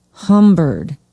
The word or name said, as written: humbird